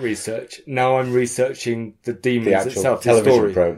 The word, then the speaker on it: daemons: So I'm writing a book on the demons, which I found out is pronounced the demons and not the daemons, as I've been saying.